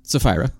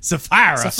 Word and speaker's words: Sapphira: Sapphira. – Sapphira. – Or if I'm just going to go ahead, the Southern Baptist pronunciation would be Sapphira.